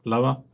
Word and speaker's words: Lower: Yeah, going from higher, you're talking about, I'm going to go to lower with Justin Lower, or Lower, as he's actually called. I just wanted to get that joke in there because it's spelled Lower.